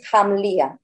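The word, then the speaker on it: Khamlia: Se escribe con K y con H, pero el sonido KH es JA. Entonces, Hamlia.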